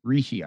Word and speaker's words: Rishi: No, his first name looks kind of like Richie, but you're not. But that's not the pronunciation. – Uh, what is the pronunciation? – Something like Rishi, I think, or I yeah.